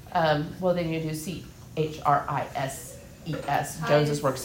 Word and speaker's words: Joneses: I thought it was just Jones, but wouldn't it be like Joneses? – Uh-huh. So we've added another syllable, so that means we're not just adding an S, we're adding ES.